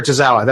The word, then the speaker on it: Tozawa: I know not how I pronounce his name, Tamina and Akira uh, Tozawa. Kira Tozawa. That's right.